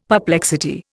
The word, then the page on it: perplexity: Indian